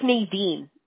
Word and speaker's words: Nadine: Mi nombre es Nadine. No hay un modo como pronunciarlo en español.